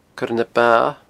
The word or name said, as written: coirenaba